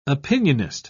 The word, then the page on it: opinionist: British English